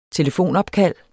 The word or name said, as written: telefonopkald